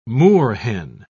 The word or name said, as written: moorhen